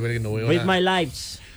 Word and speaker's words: Read My Lips: Esta canción se llama Read Me Lips. El que habla inglés. – ¿Sabe qué significa en español? – ¿Lo leí bien? ¿Lo pronuncié bien? – Read My Lips.